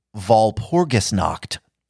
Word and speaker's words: Walpurgisnacht: Next up, Anton tells us that after one's own birthday, the two major satanic holidays are, now listen to this pronunciation, Walpurgisnacht. – Walpurgisnacht. – Walpurgisnacht. – Walpurgisnacht. – All right. I hear a lot of Walpurgisnacht and stuff out there, but I think we're trying to say Walpurgisnacht. – Walpurgisnacht.